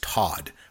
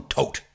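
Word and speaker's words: Toht: Well, I believe that the actually pronunciation is Todd, but it's Todd. But I always call him Tote because it's T-O-H-T.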